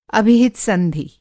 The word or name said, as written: अभिहित-संधि